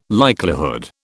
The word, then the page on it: likelihood: British English